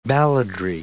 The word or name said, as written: balladry